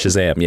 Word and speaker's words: Shazam: Is that how you say it? – Shazam, yes.